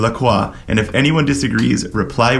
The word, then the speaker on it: La Croix: In French, you say La Croix. – Right. – If you think of the pronunciation of croissant, you will see that that is the standard pronunciation in French of like a, you know, O-I.